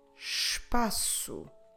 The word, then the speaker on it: espaço: Espaço, to say space, espaço.